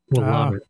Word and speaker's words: Willamette: Willamette.